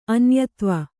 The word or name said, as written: ಅನ್ಯದೈವ